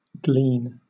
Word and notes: glean: British English

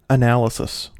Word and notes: analysis: American English

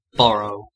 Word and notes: burrow: American English

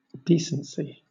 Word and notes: decency: British English

